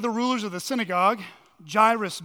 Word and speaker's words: Jairus: His name is Jairus. It rhymes with virus.